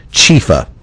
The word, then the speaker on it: Chiepha: And if you're looking for Chifa online, it's spelled C-H-I-E-P-H-A. Chifa. So there we go.